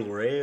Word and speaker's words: Peter Lorre: Peter Lorre is really... How do you say his name? – Lorre? – Mm-hmm.